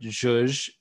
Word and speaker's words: zhuzh: Z-U-H-U-H Z-H-U-Z-H. – Z-Z-A. – So the Z and the H together make the zh. That kind of soft J sound.